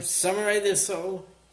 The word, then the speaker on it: Samurai del Sol: now Samurai del Sol. – Yep. – If I'm pronouncing that correctly. – Yep. Samurai del Sol.